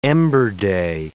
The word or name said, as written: ember day